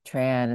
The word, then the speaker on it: Trần: And my last name, Tran, is actually very, it's pronounced pretty similarly to John.